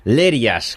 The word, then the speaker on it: Lerías: ¿Es así como se dice? ¿Lerías? – Lerías, con el acento en la E, Lerías.